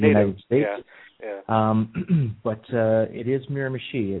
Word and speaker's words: Miramichi: Is it Miramichi, Canada? – No, it's actually pronounced Miramichi. It is an Indian name or First Nation, as we call them in Canada.